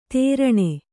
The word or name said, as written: ತೆರಣೆ